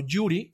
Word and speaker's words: URI: Muy talentosa, pero qué desafortunadas eh, declaraciones ha hecho últimamente, pero bueno, no, se le conoce como Yuri en inglés o URI, eh, directamente como se pronuncian sus siglas, que es el Uniform Resource Identifier.